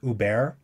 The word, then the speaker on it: Hubert: This is by, I'm going to pronounce it Hubert, but it's spelled Hubert and Zanzim.